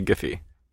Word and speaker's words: Giphy: We say Giphy. – You say Giphy? – Hard G. – Hard G. – Yeah, Obama says hard G.